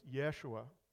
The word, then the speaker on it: Yeshua: And it's particularly poignant in that the Hebrew pronunciation of Joshua is Yeshua, which might sound familiar to you because the Hebrew pronunciation of Jesus is Yeshua.